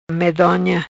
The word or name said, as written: Medonha